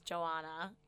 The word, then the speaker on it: Joana: Well, the thing is like, so my name is Joana. – Is it though? – Like Moana. Okay.